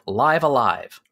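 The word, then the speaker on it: Live A Live: So for years we've been talking about, is it pronounced Live Alive or Live Alive or nope, Live Alive rhymes with Five Alive.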